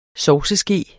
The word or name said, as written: sovseske